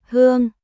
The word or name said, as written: Hương